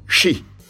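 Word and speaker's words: shi (四): Au Japon, le 4 est appelé le chiffre de la mort, parce qu'il se prononce « shi », qui signifie « mort » en japonais.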